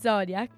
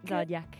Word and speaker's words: Zodiac: Innanzitutto vorrei L'ho detto male? – No, no, l'hai detto bene. – Zodiac. Zodiac.